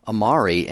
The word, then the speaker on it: Ahmari: Yeah, if you're curious, by the way, his name is spelled S-O-H-R-A-B, Sohrab, and the last name A-H-M-A-R-I, Sohrab Amari. And I'm kind of intrigued, Mark.